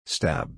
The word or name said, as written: stab